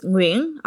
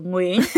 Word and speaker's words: Nguyen: Ich würde es Nguyen aussprechen. – Okay, guck, das könnte ich gar nicht, glaube ich. – Nguyen. Also einfach breiter, so Nguyen.